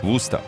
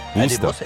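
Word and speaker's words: Worcester: Wuster. – Eine was? – Wuster. – Eine Wustersoße. – Die schreibt man nur anders, nur dass du es weißt.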